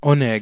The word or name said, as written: oneg